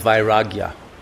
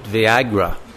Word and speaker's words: vairagya: This is the state of vairagya. We used to have a student here who would always pronounce it Viagra.